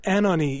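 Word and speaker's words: Anohni: Anoni, am I saying this right? This is another one that's new to me. – Anoni, yeah.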